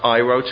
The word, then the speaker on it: Iroh: It's pronounced Iroh to rhyme with pyro.